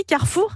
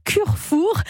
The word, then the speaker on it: Carrefour: Alors j'ai dit Carrefour, Alexandre, mais je pourrais dire Curfour, parce que Curfour. C'est comme ça que le prononce Snoop Dogg.